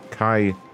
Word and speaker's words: Kei: How do I say that? K-E-I. Is that Kai? – Kai.